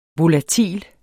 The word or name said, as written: volatil